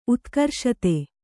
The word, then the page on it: ಉತ್ಕರ್ಷತೆ: Kannada